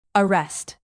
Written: arrest

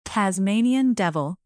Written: Tasmanian devil

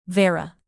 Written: vera

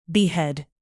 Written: behead

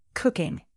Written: cooking

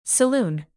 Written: saloon

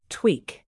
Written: tweak

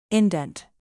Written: indent

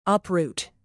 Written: uproot

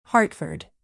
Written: hartford

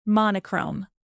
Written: monochrome

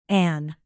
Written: anne

